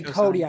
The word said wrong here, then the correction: CODA: I think you're mispronouncing Coda. I was like, does he think there's an I at the end or something?